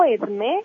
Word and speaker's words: Edmée: Mira, me llamo Edme y se escribe E-D-M-E.